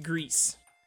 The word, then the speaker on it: Gris: It's pronounced Grease, but it's spelt Grizz, G-R-I-S.